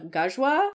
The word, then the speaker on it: Ghazwa: I also need to thank Tiffanites and Nahid John Spoon on Twitter, because they helped with the Arabic pronunciation of Ghazwa. Which it's literally spelled G-H-A-Z-W.